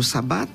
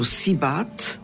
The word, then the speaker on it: صباط: والنطق متاعها يختلف من جهة لجهة. مثلا في تونس يقولوا صباط، وبعض المدن يقولوا سيبات وبرتال وسبط.